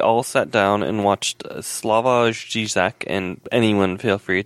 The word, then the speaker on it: Slavoj: You pronounce the J. I mean, you can pronounce it, but it's gotta be like a Y, like Slavi. – Slavoj. – Oh, Slavoj.